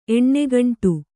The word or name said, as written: ಎಣ್ಣೆಗಂಟು